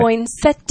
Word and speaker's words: poinsettia: But it's spelled P-O-I-N-S-E-T-T-I-A, right. – Poinsettia. – Yeah, and in English, we never spell things and say them completely differently.